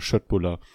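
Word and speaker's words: Köttbullar: Die beliebten, die beliebten Fleischbällchen aus Fleischbällchen Ikea. von Ikea, man, ja. Spricht man Schöttbuller aus.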